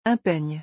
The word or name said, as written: Un peigne